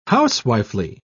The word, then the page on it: housewifely: British English